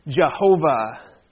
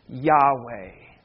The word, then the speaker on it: Yahweh: Historically, especially in German regions, they pronounce this name Jehovah. Today we might say Yahweh.